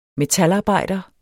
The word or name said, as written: metalarbejder